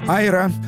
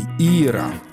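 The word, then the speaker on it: Eera: Ира. Но пишется не IRA, тогда бы она была Айра, а пишется двойное ERA. Ира.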